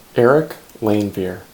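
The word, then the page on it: Eric Leinveer: English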